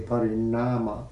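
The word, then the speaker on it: pariṇāma: See, it's not, the nama is not the nama of parinama. Nama is a retroflex and a long A, parinama, vada.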